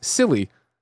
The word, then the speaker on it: PSILY: Kebab says, I pronounce PSILY as silly. With a silent P.